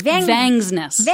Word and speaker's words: Vangsness: How do you say her last name properly? – Vangsness. – Vangsness. – Vangsness. – Vangsness. – Yes.